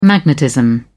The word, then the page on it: magnetism: British English